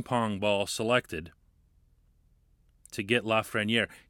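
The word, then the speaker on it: Lafrenière: I'll save you the trouble of looking it up, or for that matter, looking up the correct pronunciation. That's actually how you say it. First name looks like Alexis. It's pronounced Alexis. The last name is Lafreniere.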